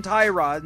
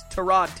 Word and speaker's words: Tyrod: He relieved the injured, I believe he pronounces it, Tyrod Taylor. Everybody calls him Tyrod, and I think he corrected it on Hard Knocks. It's Tyrod Taylor.